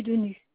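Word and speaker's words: Denus: Dupuy Denu. – Ça s'écrit? – D-U-P-U-Y. D-E-N-U-S.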